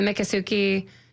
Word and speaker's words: Miccosukee: I had this incredible experience where I got to go out with the Makasuki. I'm going to mispronounce this, so my apologies to this Indian group, but the Miccosukee Indian tribe down in the Miami area, the Alligator Alley area.